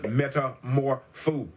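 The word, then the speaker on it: metamorphoō: The word in the Greek here for transform is pronounced metamorpho. I'll spell it for you. M-E-T-A-M-O-R-P-H-O-O. I want you to get it. Metamorpho. M-E-T-A-M-O-R-P-H-O-O. From which we get the English word metamorphosis. Listen.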